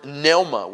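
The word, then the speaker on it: Pneuma (πνεῦμα): And in Greek, it's the word pneuma with a silent P in first. Pneuma. P-N-E-U-M-A.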